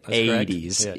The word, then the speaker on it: Aedes: Aedes.